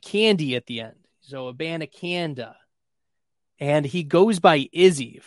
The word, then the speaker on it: Abanikanda: That's how you pronounce it. It's not a band of candy. It's not a band of conda. It's Israel, a band of canda.